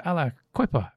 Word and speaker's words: Aliquippa: And she does say that it was written by Mervyn and Melvin Steele from Aliquippa, and that's pronounced Aliquippa.